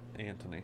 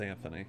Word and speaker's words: Anthony: Well, the way they pronounced it back then, it was Antony. – Okay. – That's the way they pronounced it, but it's Anthony. It's spelled Anthony.